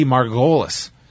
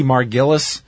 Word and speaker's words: Margolis: And it was presented by Honky Tonk Man and Cindy Margolis. And Honky Tonk Man comes out and he can't pronounce her name, calling her Candy Margillis and Cindy Margufis.